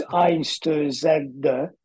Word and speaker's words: Einstürzen: Actually, for a German word, it's fairly easy to pronounce because you pronounce it as you read it. So it's Einstürzen, which is exactly how it's written, Neubarton.